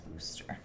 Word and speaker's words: Worcester: But first of all, this is the town that's pronounced Worcester. It's Worcestershire.